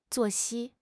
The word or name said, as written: 作息